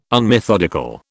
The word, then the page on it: unmethodical: British English